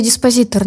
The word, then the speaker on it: диспозитор: Скорее диспозитор, наверное, да. – Диспозитор. Угу, хорошо. – Как диспозиция, может быть, да, что-то вот в этом плане.